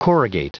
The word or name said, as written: corrugate